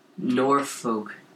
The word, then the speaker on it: Norfolk: Yes, North Fork, Virginia. – Norfolk. – N-O-R-F-O-L-K. – I'm sorry, that's not pronounced North Fork.